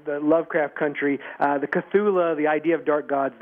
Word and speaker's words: Cthulhu: And thank you for saying that, because I didn't think I was going to be able to pronounce that. I was looking at it like Chatula. – Sure. It's meant to be unpronounceable, and we're probably all saying it wrong, which is good, because if we said it correctly, we might call Cthulhu, and then we'd all be really unhappy.